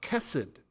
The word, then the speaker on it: chesed: It's a word pronounced chesed, and it's a way of referring to God's loving kindness or ever-present care.